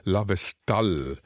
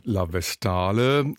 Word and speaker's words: La Vestale: In der Neuinszenierung der Oper La Vestale, französisch ausgesprochen, auf Italienisch würde es heißen La Vestale, schildert die Regisseurin den paganen, heidnisch-römischen Priesterkult der Vestalinnen als eine ziemlich totalitäre Sache.